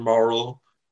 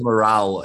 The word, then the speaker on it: Morale: His new album, Mr. Morale and the Big Steppers, or Mr. Morrell? – It's Mr. Morale. He does pronounce it several different ways on the album, but I think morale as in good morale, and the Big Steppers.